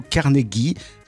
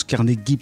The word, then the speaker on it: Carnegie: Alors, aujourd'hui les amis, je vais vous parler d'Andrew Carnegie. Oui, ça se prononce Carnegie plutôt que Carnegie, qui est passé de l'Écosse à Farnier.